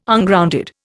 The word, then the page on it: Ungrounded: Indian